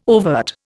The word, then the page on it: overt: Indian